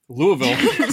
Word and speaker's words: Louisville: Because some of these, like I been to Louisville, because that's how you say it, Louisville, several times over the years.